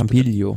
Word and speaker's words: Campiglio: Und ähm, am Mittwoch geht's von Bassano del Grappa nach Madonna di Campic, Camp, ah, ich kann es nicht aussprechen, sag du es Campiglio. Bitte. Campiglio. Wie Campagnolo. Ja, Camp, Campi, ich krieg's nicht hin.